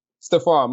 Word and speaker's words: Stephan: Lisa and Steven. I'm pronouncing Steven correct. That's the pronunciation. – Stefan. – Stefan, my apologies.